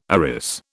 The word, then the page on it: arris: British English